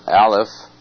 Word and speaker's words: Aleph: Now, the first verse, as I said, is an acrostic psalm, and it's the letter A, pronounced Aleph.